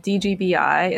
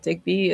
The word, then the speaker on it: DGBI: Yeah, I think we've officially decided DGBI instead of DIGBI is the way to go.